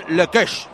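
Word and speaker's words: Lecoeuche: ¿Lo he dicho bien, Zamora? ¿Lekeš? – Sí, sí. Quentin Lekeš.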